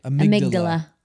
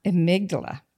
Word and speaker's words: amygdala: Amygdala. – Amygdala.